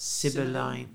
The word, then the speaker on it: Sibylline: Um, do you know the Sibylline, Sibyllian? How do you say that? – Sibylline, Sibylline. yeah.